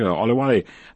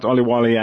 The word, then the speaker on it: Olawale: Olawale, good afternoon to you.